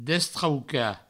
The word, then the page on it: destraucar: destrauca; Provençal